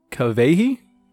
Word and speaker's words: Kawehi: So her name is, I believe it's pronounced like Kawehi. – Okay. – It's spelled K-A-W-E-H-I.